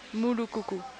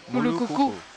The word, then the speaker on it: Mulukuku: Mulukuku. – Mulukuku. – Wow. – Yep. – That's how you pronounce it? – Well, it has an accent over the final U.